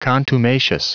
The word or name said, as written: contumacious